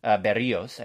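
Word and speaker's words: Berríos: But they have a whole season of Jose Berrios. Berrios, I should say, is the correct pronunciation.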